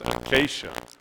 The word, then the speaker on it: acacia: And I said, well, no, it's pronounced acacia wood.